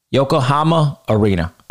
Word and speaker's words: Yokohama: Yokohama Arena.